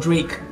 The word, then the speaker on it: Drake: 那么德雷克，我叫追克，为什么叫追克？D R A K E，念作 Drake，本来就叫追克。